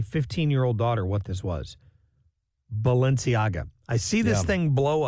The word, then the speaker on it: Balenciaga: So, yeah, Balenciaga, and you did pronounce that correctly.